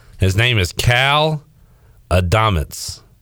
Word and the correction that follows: Adomitis: A-D-O-M-I. Oh, Adomitis. A-D-O-M-I-T-I-S.